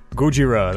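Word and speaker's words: Gojira (ゴジラ): Oh no, that's how they say it. That's Gojira. That's Godzilla.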